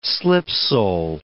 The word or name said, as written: slipsole